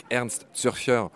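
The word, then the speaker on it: Ernst Zürcher: Tous ceux que la question intéresse, je renvoie aux épisodes qu'on a fait avec Ernst Surfer tu vois, je prononce bien son nom à hein, l'allemande, car c'est un Suisse allemand qui est le.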